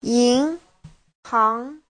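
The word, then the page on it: yinhang: yin2hang2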